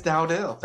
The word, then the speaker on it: Dowdell: It is Dowdell.